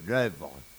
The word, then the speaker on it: œuvre: O-A-U-V-R-E, l'œuvre. O de l'œuvre, ça se prononce E.